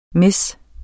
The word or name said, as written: miss